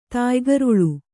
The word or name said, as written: ತಾಯ್ಗರುಳು